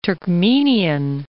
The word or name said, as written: Turkmenian